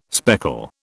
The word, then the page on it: speckle: British English